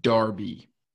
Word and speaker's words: Derby: For those of us in America, it's spelled Derby, but it is pronounced Derby, which is a rivalry match.